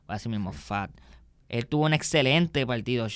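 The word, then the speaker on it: Pfaadt: El lanzador de los Diamondbacks, Brandon Fat, Sí, sé que debe estar pensando fat como de gordo, pero casi es que tuve que buscar que se pronuncia, pero se escribe P-H-A-A-D-T la P silenciada y la D silenciada en inglés.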